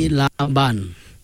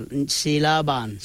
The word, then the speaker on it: Silaban: S-I-L-A-B-A-N. – Okay. Is it Silliban or is it Silliban or is it Silliban? – Silliban. – Silliban. – Silliban. Yeah. – So, do you put the emphasis on the second vocal, Silliban? – Natural. Silliban.